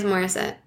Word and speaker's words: Morissette: Yeah, it is Morissette. Morissette. I don't know why I thought it was, like, French.